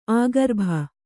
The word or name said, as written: ಆಗರ್ಭ